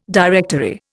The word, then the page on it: directory: Indian